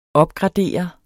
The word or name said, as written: opgradere